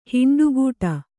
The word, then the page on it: ಹಿಂಡುಗೂಡು: Kannada